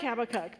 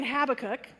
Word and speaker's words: Habakkuk: So, yeah, because I've been saying Habakkuk with the emphasis on the hab, but I know some of you say Habakkuk with the emphasis on the second. And then other people emphasize the third, Habakkuk.